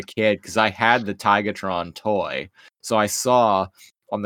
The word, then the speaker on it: Tigatron: And then the voice actor for Optimus said Tigertron, and I was like, hey, you say his name right. – He always says Tigertron.